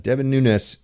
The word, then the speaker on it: Devin Nunes: I'm one of the few people that pronounces his name correctly, because he told me that's how you pronounce it. Devin Nunes